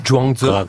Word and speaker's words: Zhuangzi: Alors, vous êtes un spécialiste de beaucoup d'auteurs chinois, notamment de Zhuangzi. – Oui, Zhuangzi.